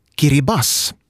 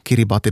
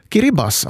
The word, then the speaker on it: Kiribati: På gilbertesiska, som det heter, det lokala språket, uttalas Gilberts som Kiribas. Där TI i slutet av då Kiribati, då man läser det så ordagrant, uttalas som S. Kiribas alltså. Så namnet är alltså en fonetisk återgivning av Gilberts på landets eget språk, men vi läser det oftast fel eftersom vi helt enkelt inte vet.